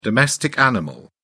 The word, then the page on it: domestic animal: British English